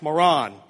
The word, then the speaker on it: Moran: You walking into an interview and they're like, hey, moron. It's pronounced moron, okay?